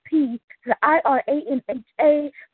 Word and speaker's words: Piranha: It's P, the I-R-A-N-H-A, precisely in the rhyme, never had an animosity.